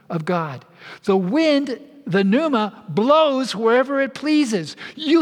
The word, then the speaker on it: pneuma: In English, we write it as P-N-E-U-M-A. It's, in Greek, it's pronounced pneuma.